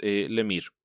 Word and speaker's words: Lemire: Es Tynion y es Lemire. – Sí, que el Lemire lo descubrimos por pronunciación propia, siendo canadiense, pues probablemente hay alguna raíz francesa que derivó en eso.